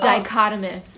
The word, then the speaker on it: dichotomous: I don't know how to pronounce this. Pichromuski? – Dichotomous. – Dichotomous. – Yeah, we struggled with that. – Like a P. – It doesn't look like it sounds.